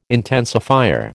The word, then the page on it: intensifier: American English